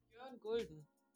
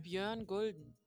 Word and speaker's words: Björn Gulden: Björn Gulden. – Say it again. – Björn Gulden. – Can't do that. – Say it one more time. – Björn Gulden.